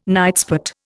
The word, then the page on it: nightspot: Indian